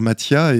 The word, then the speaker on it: Matija: ça s'écrit Matija, on peut le lire Mathia, et justement on a du mal à le prononcer, on sait même pas peut-être au départ comment ça se prononce.